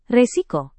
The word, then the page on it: resiko: indonesian